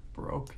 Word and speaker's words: Baroque: And a giant man wearing a Barak power armor. Am I saying that right, Brock? – Baroque? – Baroque, yeah.